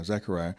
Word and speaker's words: Zechariah: Yeah, Zechariah mm-hmm.